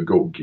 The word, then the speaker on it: GOG: Så samtidigt där hade han ju ja, hur den uttalas, GOG har jag ju alltid sagt, det sa ju GOG i Danmark,